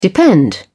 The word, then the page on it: depend: British English